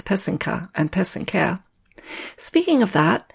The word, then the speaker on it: pysanka: The word is spelled P-Y-S-A-N-K-A. The word pesenke is the plural. It's similarly spelled, but with a Y at the end instead of an A. So it's spelled P-Y-S-A-N-K-Y. The accent is on the second syllable. An easy way to remember how to say it is that the first and third syllable rhyme. So that's the pe and the ke. Put it all together and you get pesenke.